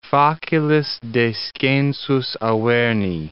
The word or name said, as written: facilis descensus Averni